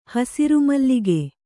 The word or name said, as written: ಹಸುರುಮಲ್ಲಿಗೆ